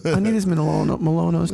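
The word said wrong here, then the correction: Manolo Blahnik: Chucky, can I get the Malonos? – Exactly. – It's like me and... – Am I saying that right? Manolo, how does this choose? Manola, I forgot. – Manolo Blahniks.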